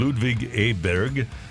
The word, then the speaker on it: Ludvig Åberg: Ludvig Åberg.